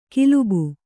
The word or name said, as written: ಕಲುಬಿ